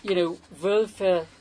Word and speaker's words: Wölfe: You have often, Wölfe has two syllables, whereas wolves has one syllable, right? So it might look the same length, but it's only got one syllable.